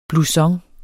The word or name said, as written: blouson